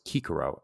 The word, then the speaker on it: Cicero: I'm emphasizing that C is a hard C, as it would be in Latin back in the day. As I've said before in the past, Caesar would be pronounced as Caesar. Cicero would be pronounced as Kikero, and so on and so forth.